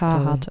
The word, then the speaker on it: Carhartt: heißt das Carhartt, ja, mit C-A-R, ja, genau.